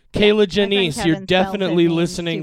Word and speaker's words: Kierla: Kayla. Yeah, it's spelled K-I-E-R-L-A, but apparently it's pronounced Kayla.